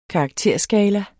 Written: karakterskala

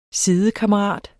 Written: sidekammerat

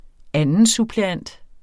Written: revisor

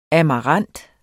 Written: amarant

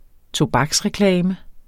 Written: tobaksreklame

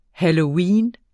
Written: halloween

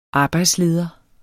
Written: arbejdsleder

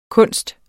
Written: kunst

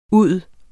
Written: ud

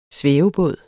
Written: svævebåd

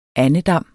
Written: andedam

